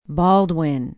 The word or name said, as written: baldwin